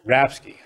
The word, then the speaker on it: Rapske: Rapsky.